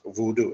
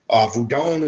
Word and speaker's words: Vudon: Keona Carter says, "And isn't Vudon pronounced voodoo?" It just depends on the tradition and the language. Vudon is the more ancient pronunciation of it, going back to Africa. Voodoo is more modern French, and it deals more with the hoodoo voodoo terminology that you get from the more modern voodoo practitioners.